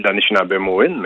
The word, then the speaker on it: Anishinaabemowin: Oui, on dit, on dit l'Anishinaabemowin, mais en français, oui, ça, ça se dit Excuse-moi, j'étais pas capable de bien prononcer.